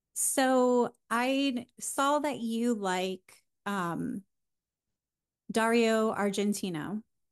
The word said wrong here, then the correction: Argento: or no, Argento. I'm saying that wrong.